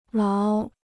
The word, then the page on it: 牢: láo